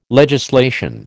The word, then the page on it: legislation: American English